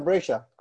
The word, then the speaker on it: Brescia: Brescia.